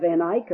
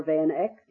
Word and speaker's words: Van Eyck: And you can have Van Eyck or Van Eck, depending how you want to pronounce his name.